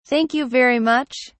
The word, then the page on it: thank you very much: British English